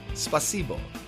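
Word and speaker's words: Spasibo (спасибо): I think the accent goes on the second syllable. Spasibo.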